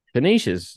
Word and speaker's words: Canisius: Is it Canisius? – Canisius, very good.